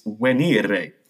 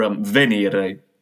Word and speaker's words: venire: It's venire, or venire, depending on your preferred pronunciation of Latin.